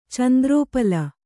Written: ಚಂದ್ರೋಪಲ

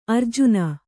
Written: ಆರ್ಜನ

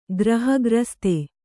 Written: ಗ್ರಹಗ್ರಸ್ತ

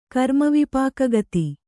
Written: ಕರ್ಮವಿಪಾಕಗತಿ

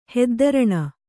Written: ಹೆದ್ದರಣೆ

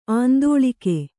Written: ಆಂದೋಲಕ

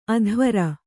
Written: ಅಧ್ವರಿ

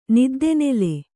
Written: ನಿದ್ದೆನೆಲೆ

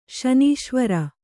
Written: ಶನೀಶ್ವರ

